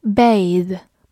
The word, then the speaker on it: bathe: 这个用法一般出现在英式英语当中，在美式英语里更常用 bathe。末尾多了一个 e，发音也发生了变化。